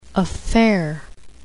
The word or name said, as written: affair